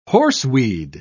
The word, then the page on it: horseweed: British English